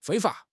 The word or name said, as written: 非法